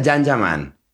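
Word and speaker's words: Can Yaman: Se escribe Yan Yaman, se pronuncia Yan Yaman.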